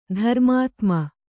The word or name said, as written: ಧರ್ಮಾತ್ಮ